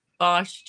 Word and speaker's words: Bosch: Is it Bosch or Bosch? – Bosch, just like the basketball tools?